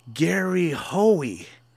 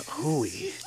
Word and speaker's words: Gary Hoey: And he goes, I wanted to tell you it's pronounced Gary Hoey, not Hooey.